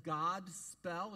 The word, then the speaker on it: godspell: It looks like God spell. It's pronounced Godspell, long O sound. It's a mashup of two words the word goad, meaning good, the word spell, meaning story or message.